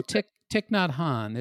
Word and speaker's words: Thich Nhat Hanh: Oh, Thich Nhat Hanh. It's Thich, and then the middle name is Nhat.